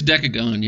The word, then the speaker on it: Dekogon: How do I say it, right? – Dekogon? I think it's Dekogon, yeah.